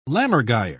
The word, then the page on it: lammergeier: British English